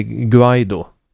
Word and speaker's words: Guaido: Det ska uttalas med ett hårt G som är Guaido.